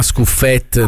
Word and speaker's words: Scuffet: Tutta la sera glielo dico, gli dici si chiama Scuffet, non è francese, Scuffet, e non si chiama Scuffet. – Scuffet, oh, è friulano.